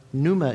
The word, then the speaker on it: pneuma: Now, Numa is spelled P-N-E-U-M-A, but he spelled it N-O-O-M-A.